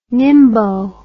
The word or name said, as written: nimbo